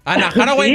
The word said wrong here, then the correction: Anne Hathaway: ¿Cómo se escribe? ¿Cómo se pronuncia? – Anne Hathaway. – ¿Hi? ¿How? – Anne Hathaway. – Como hack dog. Anne Hathaway.